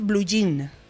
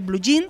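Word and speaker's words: bluyín: Es blue jean. – Un blue jean y se refiere a pantalón vaquero. – Con el acento en la I, con Y y con... o sea, eso es B-L-U-Y-I-N.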